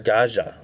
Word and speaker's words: Gaja: Mark with a soft J. Gaja.